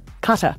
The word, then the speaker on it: Qatar: It's not Qatar. – Qatar. – Yeah, like a sneeze.